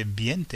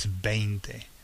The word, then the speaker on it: veinte: But of course, we English people tend to want to put the E first, so we tend to say veinte, and it's not, it's veinte, okay? Which rhymes with twente, twente, veinte, okay?